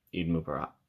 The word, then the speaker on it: Eid Mubarak: Eid Mubarak.